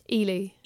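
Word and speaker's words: Ehle: How the fuck do you pronounce her last name? – Ely. – Ely. – Yeah, Jennifer Ely. – I was saying Eel for years.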